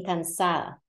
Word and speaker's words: cansada: You're not really going to pronounce the D, so you just kind of accent that last. The accent would normally fall here, cansada. But when you drop the D, it ends up being cansada or cansada.